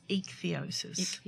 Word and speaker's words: ichthyosis: Ichthyosis.